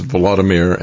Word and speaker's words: Volodymyr: And speaking of our pal there, Vladimir Zelensky, Volodymyr, I guess. He's Volodymyr and Putin is Vladimir. So different emphasis on different syllables.